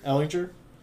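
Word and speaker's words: Ehlinger: Sam Ellinger, am I saying that right? – Ellinger.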